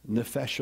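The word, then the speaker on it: nefesh (נפש): It's like what in Hebrew we would call the nefesh of God. It rhymes with refresh of God.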